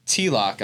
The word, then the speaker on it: Tilak: It's Tilak.